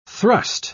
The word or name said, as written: thrust